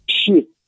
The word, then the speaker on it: Xi: Ši. – Je vidět, že jí zanedbal hodiny čínštiny na základní škole, kdyby si nevěděl, že to je ši, kdo to vyslovuje.